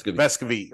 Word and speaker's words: Vescovi: You know, one of the things that I want to give a shout out to our defense for, and I'm probably pronouncing his name wrong, but Santiago Vescovi. – Vescovi. – Okay. I knew I was pronouncing it wrong.